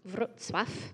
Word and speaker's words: Wrocław: So then after we were 6 years in Czech, now we've been in Poland, in a city that looks like Wroclaw, W-R-O-C-L-A-W, but it's pronounced Wrocław.